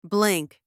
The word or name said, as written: blink